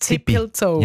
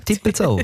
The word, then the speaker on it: Tippilzou: Aber also der Urs, der wahrscheinlich dort wohnt oder einfach sehr verbandelt ist mit dem Ort, hat ihn uns geschrieben, so wie er ihn aussprechen würde. Und er hat wirklich T-I-P-P-I-L-Z-O-U geschrieben. – Also ist Zelt. – Tippelzau. – Ja, Tippelzau. – Tippelzau.